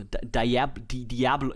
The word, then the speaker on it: Diablo: Ich sag lieber Diablo. Aber die Amis sagen mir so, Diablo, ich kann das nicht aussprechen. Ich kann das, geht nicht.